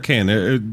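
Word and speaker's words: Cannes: So when I look at your LinkedIn, I see a long list of recent accolades where, you know, your 2012 – is it Con or Can? There's always a debate. Are you French? Are you not French? – I pronounce it con.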